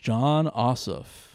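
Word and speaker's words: Jon Ossoff: And like, I was like, bringing to the stage, John Ossoff.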